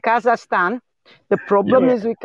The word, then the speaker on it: Kazakhstan: oh yeah that's a hard one to pronounce kazakhstan, kazakhstan.